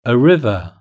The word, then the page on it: a river: British English